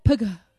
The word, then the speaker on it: Pugh: Do you know how you pronounce her surname? Florence Pugh. Yes? Florence Pugh. That is how you pronounce her surname. I was shocked, because I was like, I feel like I'm not pronouncing her surname correctly, so I went and searched. The correct pronunciation is Florence Pugh. That is how you pronounce it.